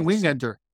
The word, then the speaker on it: Wingenter: But in terms of like the bullpen, Trey, how do you say his last name? Trey Winger? – Wingenter? – I've been saying Wingenter. – Wingenter? Okay.